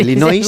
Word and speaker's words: Illinois: Es un estado de Estados Unidos que se pronuncia así. Es sí, Illinois, según tú. Pero es Illinois.